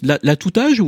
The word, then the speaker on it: L'atout-âge: L'atout-âge ou l'atout-âge ? – Eh bien, j'aime bien la liaison parce que l'atout-âge, ça dit bien ce que ça dit.